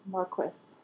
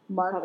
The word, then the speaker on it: Marquess: Then Tom Wilkinson comes to, which is the dad, I guess, Mr. Queensberry. – Marquess of Queensberry. – Marquess. – Marquess. – How they say it. Marquess.